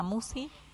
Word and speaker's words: Musci: El canal lleva mi nombre, Marisa Musi. La lista de reproducción es Serenamente. – ¿Cómo se escribe Musi? – M-U-S-C-I latina.